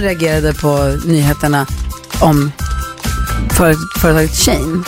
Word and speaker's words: Shein: Det heter Shein. – Shein. – Som she och in.